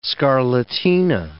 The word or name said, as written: scarlatina